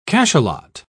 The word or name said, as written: cachalot